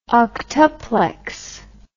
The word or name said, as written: octuplex